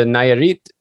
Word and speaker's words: Nayarit: And let's talk about the book, A Place at the Nayarit. Is that, did I pronounce that correct? – Yes. Yes.